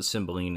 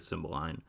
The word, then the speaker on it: Cymbeline: They pronounce it Cymbeline in this movie. I've also heard Cymbeline.